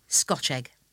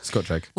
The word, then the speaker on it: Scotch egg: And you have to pronounce it Scotch egg. As if it's just one word. – Yeah, Scotch egg.